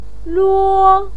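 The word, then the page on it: luo: tone 1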